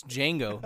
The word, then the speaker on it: Django: It's Django. There's D is silent.